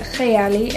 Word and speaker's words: Kayali: In Arabic, you actually pronounce it Kayali.